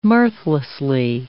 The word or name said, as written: mirthlessly